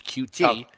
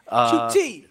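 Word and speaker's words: Coutee: Well, Watson's going to gun it, and the correct pronunciation is QT. – QT.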